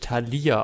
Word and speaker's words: Thalia: Ich habe über Taya bestellt. – Das ist schön. Wobei man das eigentlich immer anders, da spricht man eigentlich anders aus, da spricht man eigentlich Thalia aus.